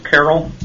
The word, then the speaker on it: Carroll: Yes, my name is Kip Carroll. My last name is spelled C-A-R-R-O-L-L.